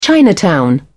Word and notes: chinatown: British English

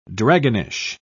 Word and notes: dragonish: British English

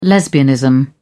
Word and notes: lesbianism: British English

